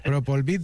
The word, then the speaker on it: Propolvit: Me he dejado aquí la V, doctor, bien por corregirme. Propolvit Defense.